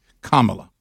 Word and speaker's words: Kamala: And so did – there were different pronunciations, but it is Kamala.